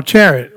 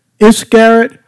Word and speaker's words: Iscariot: And supper being ended, the devil having now put in the heart of Judah Iscariot, or chariot, or what, how you pronounce it? Chariot? Iscariot.